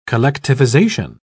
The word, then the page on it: collectivisation: British English